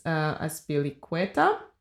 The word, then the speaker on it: Azpilicueta: Also, she over there, she collaborates closely with the artist Mercedes Azpilicueta. Did I say it right? – Yeah, perfect. Cool.